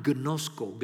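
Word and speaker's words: gnosko (γινώσκω): So that word know, K-N-O-W, that four-letter word there, know, in the original language is a word that's pronounced gnosko, gnosko.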